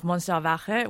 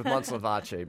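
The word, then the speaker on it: Montsalvatge: Yeah, you're giving it a more Catalan pronunciation. – I'm not even going to try it. – Yeah, I'm just going with Monslavace because, you know, the real pronunciation looks nothing like the way it's spelled. You know, it's funny.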